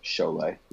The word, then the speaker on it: Šiauliai: So that's Šiauliai. The town I'm into. It looks really weird, but it's just pronounced Šiauliai.